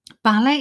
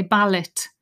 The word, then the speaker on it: ballet: Ballet itself is maybe a word that you don't pick up when you're listening because it's the French pronunciation. It has the T at the end in the spelling. Lots of my say, students say ballot. Um, it's a really common mistake.